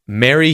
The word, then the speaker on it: Merry: Be careful with its pronunciation, though. Don't confuse it with Mary, as in the name, which is the long air vowel sound, as opposed to the short e in Merry, or with the vowel sound a in marry, as in to marry someone.